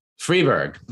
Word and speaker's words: Fribourg: Freiburg? How do you say that? We say oh. Freiburg. – Freiburg. There we go. It's not German.